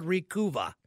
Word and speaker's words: Recuva: They also make a product called Recuva. R E C U V A. I think it's kind of like supposed to rhyme with recover.